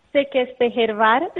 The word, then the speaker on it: Székesfehérvár: Pues el nombre de la ciudad es Székesfehérvár, que es, bueno, la capital de una de las regiones de aquí de Hungría. – Székesfehérvár, más o menos, ¿no? – Sí, muy bien.